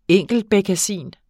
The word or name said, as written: enkeltbekkasin